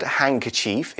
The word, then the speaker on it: handkerchief: The word handkerchief is spelled H-A-N-D.K-E-R-C-H-I-E-F, a long one. Handkerchief, we don't really pronounce the D, we say handkerchief.